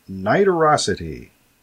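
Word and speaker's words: nidorosity: And the word is niterosity.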